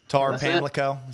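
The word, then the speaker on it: Tar-Pamlico: Tarpamlico, is that where you're going? – That's it.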